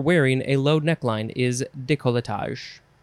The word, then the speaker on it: décolletage: Next is décolletage. Décolletage, or you can say décolletage, or you can say, what? Are we combining things here? Décolletage. You can also say that, décolletage, but that's removing a whole syllable. It is spelled D-E-C-O-L-L-E-T-A-G-E, décolletage.